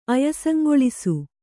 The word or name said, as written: ಅಯಸಂಗೊಳಿಸು